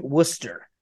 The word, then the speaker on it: Worcester: No, R is silent. Worcester.